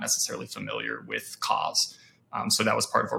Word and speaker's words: COZ: Tell us a little bit about Cause. Hopefully I pronounced that correctly. – Yeah, you're good.